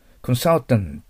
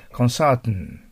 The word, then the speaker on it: consultant: コンサルタントというカタカナで読んじゃダメですよ。 consultant。で、最後の t はほとんど発音しないぐらいです。 consultant。